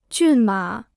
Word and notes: 骏马: jùn mǎ

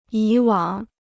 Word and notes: 以往: yǐ wǎng

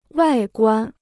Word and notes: 外观: wài guān

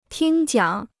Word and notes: 听讲: tīng jiǎng

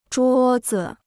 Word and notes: 桌子: zhuō zi

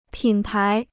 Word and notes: 品牌: pǐn pái

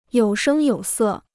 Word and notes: 有声有色: yǒu shēng yǒu sè

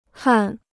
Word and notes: 焊: hàn

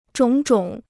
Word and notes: 种种: zhǒng zhǒng

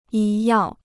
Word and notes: 医药: yī yào

